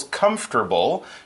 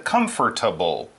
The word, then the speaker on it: comfortable: I said it with three syllables, comfortable. She said comfortable. But both of those are okay, it just kind of depends where you come from. But you can say comfortable or comfortable.